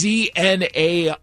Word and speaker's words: Znaimer: Sam Znamer is a Canadian, and is that how you say it? It's Znaimer.